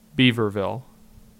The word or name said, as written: Beaverville